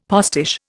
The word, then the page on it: postiche: Indian